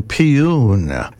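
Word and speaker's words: Paean: Oh, according to AI, pronunciation Piyun is a song, poem, or expression of enthusiastic praise, triumph, and thanksgiving.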